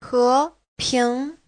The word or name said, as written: he2ping2